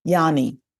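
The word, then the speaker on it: Yhane: Yanni, like Johnny, but with a Y.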